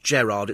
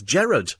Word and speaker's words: Gerard: It's not Gerard. It's Gerard. It's G-E-R. not double R, it's Gerard.